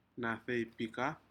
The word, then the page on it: nadeipikad: Aneityum